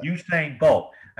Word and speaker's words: Usain Bolt: Usain Bolt.